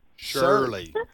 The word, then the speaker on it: Shirley: She didn't say Cersei. – I'm in Shirley. – Shirley. – Shirley. – Okay. – Shirley. – How do you spell that for us? – S-H-I-R-L-E-Y. – Just okay. like the girl's name.